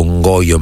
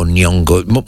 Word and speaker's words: Nyong'o: Lupita, io dico Ngoio, ma in realtà poi è difficile pronunciare, perché sarebbe Nyong'o, Nyong'o.